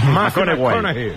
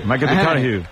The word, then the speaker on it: McConaughey: Que hay que ir corrigiendo la pronunciación. – McConaughey. – McConaughey.